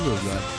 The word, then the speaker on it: Yozora: Não, é Yozora.